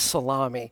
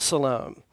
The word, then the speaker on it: Salome: When the Sabbath was over, Mary Magdalene, Mary the mother of James, and I always used to call this Salome, but I think you pronounce it Salome, which means peace.